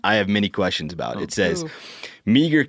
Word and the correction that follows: Meagher: County Undersheriff Jeremy West. – Mar. – Mar? – It's pronounced Mar. – You say that Mar? – Yeah. – Boy, I butchered that then. – Dude, I didn't even know. – Little Montana history, Thomas Mar. – Okay. – I live next to that street, and I was calling it Meagher for a long time.